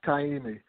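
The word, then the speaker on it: Caymmi: Kaimi. I'm not sure if I'm pronouncing Kaimi. – Kaimi, okay.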